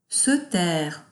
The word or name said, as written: se taire